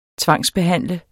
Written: tvangsbehandle

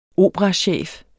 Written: operachef

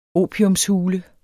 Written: opiumshule

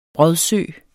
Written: brodsø

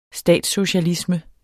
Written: statssocialisme

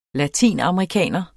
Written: latinamerikaner

